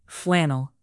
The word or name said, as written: flannel